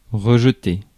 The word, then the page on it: rejeter: France; [ʁǝʒ.te]